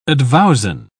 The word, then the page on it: advowson: British English